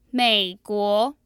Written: meiguo

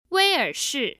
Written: weiershi

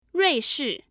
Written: ruishi

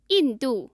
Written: yindu

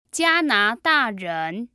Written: jianadaren

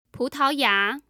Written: putaoya

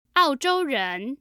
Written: aozhouren

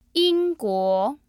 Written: yingguo

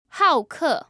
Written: haoke

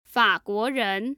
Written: faguoren

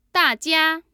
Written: dajia

